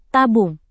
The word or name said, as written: tabung